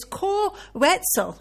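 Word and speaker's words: Koe Wetzel: Now, I have found out how to pronounce this guy's name. It's Co Wetzel.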